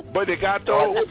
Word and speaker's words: obrigado: Obrigado. – That's how you say it. – Obrigado. – He just made that up. Obrigado. – He's right. It's obrigado.